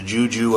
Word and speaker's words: juju: There's the juju of all sorts of new people that might be your friends. – Which rhymes with goo-goo.